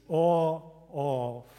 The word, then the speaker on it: OAuth: For everybody else, my pronunciation might be bad. It's OAuth. I'm serious. Like, at least in America, when I say OAuth, they don't understand.